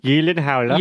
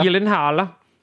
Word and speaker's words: Gyllenhaal: And his last name is actually pronounced Gyllenhaal. – Gyllenhaal. Yeah. – Again, can you confirm this? – Gyllenhaal.